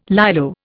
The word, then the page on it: Lilo: Indian